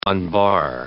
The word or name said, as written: unbar